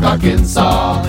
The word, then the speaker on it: Arkansas: and also in Little Rock, Arkansas. It ends with an S, but don't say it, boy, when mentioning Springfield, Illinois.